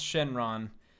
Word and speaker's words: Shenron: Just how it's pronounced, really, it's, they're both pronounced Shenron, but like with the Japanese pronunciation, hmm.